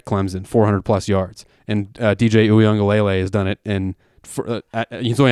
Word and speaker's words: Uiagalelei: The backup, who I finally learned how to pronounce this kid's name, DJ Uiagalelei, I just nailed it, by the way. – Very good.